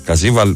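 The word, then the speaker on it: Caviezel: Ο Καζίβαλ προφέρεται. Πιστεύω ότι το προφέρω καλά.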